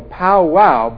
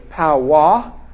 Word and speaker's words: Powaw: Last week, I'm still not sure how to pronounce this name word exactly, because I go powwow, but it's powwah or powwah, P-O-W-A-W. In many, if not all, of the groups where he was going, there could be a powwah, which was like a medicine man or a witch doctor, if you will, in some groups.